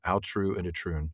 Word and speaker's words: Aotrou and Itroun: You recently, The Story of Kullervo uh, and The Lay of I, I still think I'm going to mispronounce this every time, but I think I manage it. Aotrou and Itroun. – You did it right.